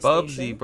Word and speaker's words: Bubsy: Next, we have Bubsby 3D, a.k.a. Bubsby 3D Fur-Bitten Planet on the PlayStation. – Bubsy, bro, not Bubsby.